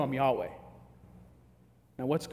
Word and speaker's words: Yahweh: It's the best we can think to pronounce it is Yahweh.